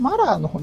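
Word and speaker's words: Mueller: まあ、モラー、まあ、これ発音はマラーの方に近いんですけど、まあ、マラー特別検察官のこの、調査書はですね、出版されてますので、我々も全文は読めます。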